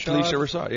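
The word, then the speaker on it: Phylicia Rashad: And I think Felicia Rashad, did I say her name right? – I think so. – Felicia Rashad. – Felicia Rashad, yeah.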